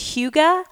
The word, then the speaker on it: hygge: Have you heard the word H-Y-G-E-E? I'm pretty sure it's pronounced hygge, but it's this Danish concept about creating this warm atmosphere with the people that are around you, with the things that you have in your home.